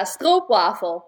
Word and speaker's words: stroopwafel: Am I going to say this correctly when I say stroopwafel? – Yeah. Stroopwafel. That's what you say it in Dutch. Okay. People here will say stroopwafel.